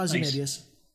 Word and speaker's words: Ozymandias: Did I say it right? – Yeah, Ozymandias.